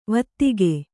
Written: ವೈದಿಕ

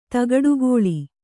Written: ತಗಡುಗೋಳಿ

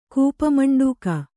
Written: ಕೂಪಮಂಡೂಕ

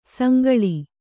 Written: ಸಂಕುಲ

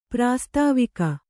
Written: ಪ್ರಾಸ್ತಾವಿಕ

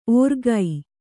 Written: ಓರ್ಗೈ

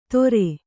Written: ತೊರೆ

